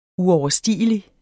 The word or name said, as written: uoverstigelig